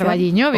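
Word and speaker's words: O Carballiño: Vamos a hablar de la fiesta del pulpo, que se celebra en Ocarvalliño, no lo sé pronunciar. – Ocarvalliño, bien.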